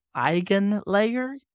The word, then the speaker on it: EigenLayer: I am not entirely sure how to pronounce it, but it looks, and I think it sounds like Eigenlayer. – I believe that's the correct pronunciation, yes.